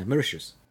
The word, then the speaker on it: Mauritius: I've got one guy, Oliver, who attends my classes from an island, I think it's pronounced Mauritius, in the middle of the Indian Ocean. – Yeah, Mauritius.